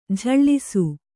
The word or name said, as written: ಝಳಿಸು